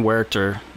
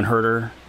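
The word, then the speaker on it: Huerter: They were gonna put Kevin Werter. I can't not say his name in Spanish. They put that up in Milwaukee, the Rick Roll. Did you see the Rick Roll thing? The Rick Astley, Kevin Herter? – No.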